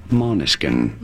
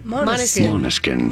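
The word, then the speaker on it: Måneskin: How do you say it? Maneskin. – Maneskin. – Maneskin. Pretty straightforward.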